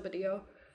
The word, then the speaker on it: Vadillo: It was first found in the region of Vadillo, but with the Barcelonan pronunciation of Spanish, the V is pronounced as a B, so Badillo.